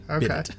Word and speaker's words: bidet: Yep, for those of you who have never seen a bidet spelt, it's B-I-D-E-T. Okay? – Bidet. Bidet.